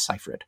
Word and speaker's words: Seyfried: I believe I looked up how to pronounce that correctly, and I believe it is Seyfried, who is fairly talented, I think, anyway.